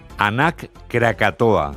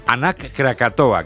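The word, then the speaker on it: Anak Krakatau: Nos vamos a Indonesia, donde la erupción del volcán, vamos a ver si lo decimos bien, Anak Krakatoa. – Lo has dicho bien. – ¿Lo he dicho bien? – Sí, parece euskera, pero es indonesio. Sí. – Vale, perfecto, bye. Es que recasco. Anak Krakatoa,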